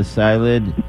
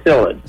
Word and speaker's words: psyllid: The psyllid? – It's a psyllid.